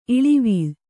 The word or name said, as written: ಇಳಿವೀಳ್